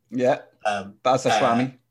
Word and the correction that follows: Ashmawi: Is it Ashwami or is it Ash? – Ashmawi. – Yeah, you see, there you go. – But I had to pronounce it the wrong way first.